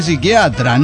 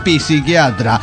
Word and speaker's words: psiquiatra: No, se dice psiquiatra, no psiquiatra. La P no se pronuncia, señor.